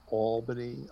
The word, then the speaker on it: Albany: We have Albany calling in. Welcome aboard. – Excuse me, Bill. It's Albany. I grew up there, so I am privy to the official pronunciation. It's Albany.